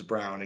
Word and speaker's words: Braun: It's spelt like Braun, but it's Brown in case you guys are trying to figure out who I'm talking about.